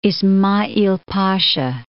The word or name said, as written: Isma.'al Pasha